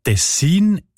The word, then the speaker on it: Tessin: Ja, tatsächlich variiert die Betonung von dem Kantonsnamen, der sich übrigens vom gleichnamigen Fluss ableitet, von Region zu Region oder sogar von Person zu Person. Die Variante Tessin ist häufiger.